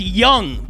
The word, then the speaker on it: Duong: Alex's last name is pronounced Young, but it's spelled D-U-O-N-G.